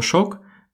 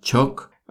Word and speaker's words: shock: Кстати, насчет слова «шок» взято слово из английского языка, пишется оно точно так же, как и в английском – и в зависимости от того, в какой части Испании вы находитесь, это слово произносится по-разному. Например, на юге чаще произносят именно как шок, либо чок. Э, в центральной части Испании можно услышать даже сок.